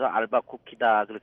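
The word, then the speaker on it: Albuquerque: 예, 좀 발음하기가 좀 힘든데 제가 소개할 때는 늘 알밤도 주고 쿠키도 준다. 그래서 알바 쿠키다. 그렇게 제가 소개를 하고 있습니다. – 들으신 것처럼 뉴멕시코주 인구의 절반 이상이 앨버커키에 집중되어 있을 만큼 앨버커키는 뉴멕시코에서는 가장 큰 도시인데요.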